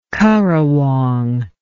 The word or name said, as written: currawong